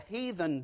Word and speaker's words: heathen: I remember when I was in school, James Rogers beat me up because I said as the, here we go, the southern pronunciation of that word, the heathen do. It doesn't have an R in it, does it? It doesn't have an R in it. "So as the heathen do."